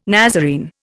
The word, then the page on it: Nazarene: Indian